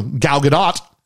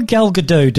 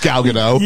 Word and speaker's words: Gadot: Although Gal Gadot do sound better. – It's the way you want it to sound, isn't it? You want it to be pronounced Gal Gadot. – Yeah. – But it's not.